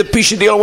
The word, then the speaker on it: Pisciadillo: And he goes, I didn't know how to pronounce it. I go, you got to say Pisciadillo. You guys got any cracks inside Petco? Maybe the Pisciadillo went inside the hole and got stuck.